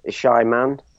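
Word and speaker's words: Xiamen: So it's pronounced Shyman, I think. Caroline's just told me that, Shyman, although it starts with an X and an I.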